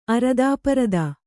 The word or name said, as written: ಅರದಾಪರದಾ